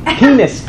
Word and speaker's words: Pines: Pinis. P-I-N-E-S. – P-I-N-E-S. – He was an Israeli. It's an Israeli name. – Pines in English, huh? – I pronounce it the way it's pronounced.